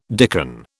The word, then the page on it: dicken: British English